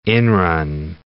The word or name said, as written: inrun